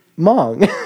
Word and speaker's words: Hmong: And I said, oh, that's how you say it. Hmong.